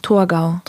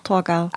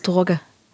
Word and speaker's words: Torgau: Wie spricht man es richtig aus? – Torgau. – Torgau. – Also ob das jetzt richtig ist, also... – Ich meine jetzt quasi im Slang. – Ne, dann ist es Torge.